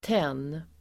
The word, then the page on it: tenn: [ten:]